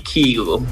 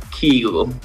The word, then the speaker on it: Kygo: Of ja, Kygo, spreek ik dat nog goed uit? Het is eigenlijk iets anders, toch? – I guess it's just like the Norwegian pronunciation would be Kygo. – Oké, in het land waar hij vandaan komt, waar het dus echt correct uit wordt gesproken, is het Kygo.